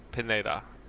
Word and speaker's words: Pineda: Yes, it's Daniela, or Daniela, yeah, because it's in English. Pineda.